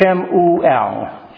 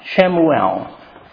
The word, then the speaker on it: Shemuel: Of course, we just pronounce it Samuel. It's Shemuel. Shemuel. And the second word, you can see the pronunciation of it there in the natural sense, Shemuel.